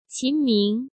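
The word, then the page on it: Qin Ming: Chinese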